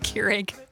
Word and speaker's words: Keurig: I'm not a fan of the Krieg. – Keurig? – Yeah. Yeah. – It's pronounced Keurig.